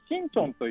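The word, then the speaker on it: シ ン チ ョ ン (신촌): シンチョンという町に来てまは新しい村と書いて新町と読むところですね。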